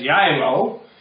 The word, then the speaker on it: Geilo: Now, that's a funny-sounding name, that doesn't seem to us it would be pronounced as Jaimo, because it's spelled G-E-I-L-O, but pronounced Jaimo, as if the G is a Y.